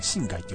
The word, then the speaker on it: 新海: 新しいに改めると書いて、新海って読むんですけどね。